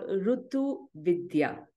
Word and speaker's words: Ritu Vidya: So the book is called Ritu Vidya.